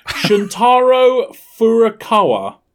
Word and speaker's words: Shuntaro Furukawa: So yeah, Nintendo president, and I'm going to butcher this, Shuntaro Furukawa I think that's pretty good.